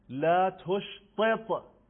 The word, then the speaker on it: لا تشطط: مثلا کلمه لا تشطط، ت اول ت دو نقطه، دو تا ت بعدی ت دسته دار، لا تشطط، که اینا رو من نیوردم توی این اسلاید ها چون که مشکل بود، شاید یه ده نتونن تلفظ بکنن.